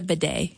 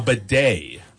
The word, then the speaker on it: bidet: It's a bidet. – A bidet. – Yes. Do you even know what a bidet is? What it does? – No, I have no idea what a bidet is.